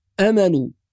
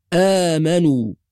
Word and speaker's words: آمنوا: أيضا كلمة آمنوا بعض القراء يقرأها بدون ألف، يقول آمنوا، لا يثبت الألف، والصواب أن الألف تنطق وتمد بمقدار حركتين، فنقول آمنوا.